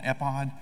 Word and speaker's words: ephod: He puts on what's called an ephod. E-P-H-O-D, I don't know how you pronounce that. Ephod, that's the way I'm pronouncing it.